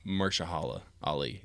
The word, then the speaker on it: Mahershala Ali: Mahershala Ali is his name. That's his name. – Yeah, I'll believe you, because I don't know. – And like 98% sure that's how it's pronounced.